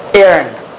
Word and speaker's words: Aaron: Aaron. Okay, the H is gone. Right? Aaron. They usually write it A-A-R-O-N.